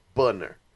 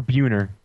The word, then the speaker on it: Buhner: Yes, Buhner. B-U-H-N-E-R. – Or Buhner. – No, Buhner. – Buhner? – Yeah, it's Buhner.